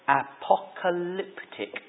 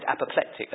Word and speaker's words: apocalyptic: This letter, however, is unusual, because it is mostly written in the genre of apocalyptic, said it correctly. I said apoplectic earlier.